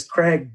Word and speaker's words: Craig: Like my first name is Craig, but living in Peru, people can't phonetically pronounce that. So I had to go by my middle name, which is Martin, and I just threw an accent on it.